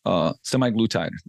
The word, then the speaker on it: semaglutide: Semaglutide.